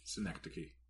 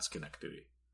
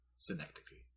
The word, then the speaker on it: Synecdoche: Synecdoche, not Schenectady. Synecdoche.